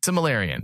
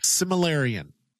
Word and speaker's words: Silmarillion: Silmarillion.